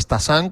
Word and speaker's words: Stassin: Stassin, o Stassin, como se pronuncia en francés,